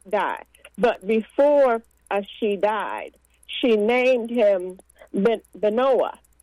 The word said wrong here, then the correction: Benoni: Let me see how it's pronounced. She named him Benoni. She named him Benoni. Okay, let's pronounce it like that.